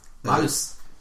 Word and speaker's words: Marth: No, say it like you say it in Japanese. – What? – Say Marth. – Marus. – That's perfect.